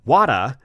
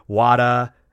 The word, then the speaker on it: Wada: And Wada, in certain pronunciations, sounded a lot like Vader, which is also, as a quick side note, also where George Lucas got Vader from.